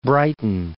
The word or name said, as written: Brighton